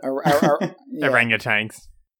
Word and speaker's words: orangutans: How do you say that? – Orangutans.